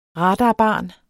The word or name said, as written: radarbarn